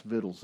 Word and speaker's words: victuals: VICTUALS for you in the Old King James Version. That's pronounced vittles in England.